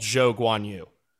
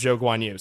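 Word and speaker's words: Zhou Guanyu: Literally, the day that episode came out, he clarified that he preferred to be called Zhou Guan Yu. Right after I said it doesn't really matter. I did this whole thing about how to pronounce his name correctly and how it doesn't matter which order you do it in. And then the next day, he says he prefers Zhou Guan Yu. So that is now the official Yankshaft pronunciation.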